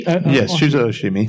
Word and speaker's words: Shuzo Oshimi: So it's by Shuzo Oshimi. – Shuzo Oshimi. – Thank you, Jesus.